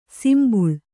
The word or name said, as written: ಸಂಭಾಳಿ